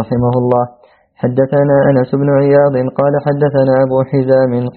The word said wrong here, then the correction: أبو حازم: أبو حازم سلام.